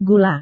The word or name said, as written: gula